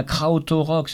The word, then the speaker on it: Krautrock: Je prononce pas bien. Krautrock, c'est un...